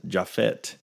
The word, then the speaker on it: Japhet: Mother Leeds has been identified by some as Deborah Leeds on the grounds that Deborah Leeds' husband, Japhet, I think that's how it's pronounced, J-A-P-H-E-T, Japhet Leeds, named 12 children in the will he wrote during 1736.